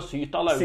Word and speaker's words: Sytterlaust: Ja, jeg tror du uttaler Sytterlaust. – Sytterlaust, ja.